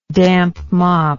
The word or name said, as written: damp mop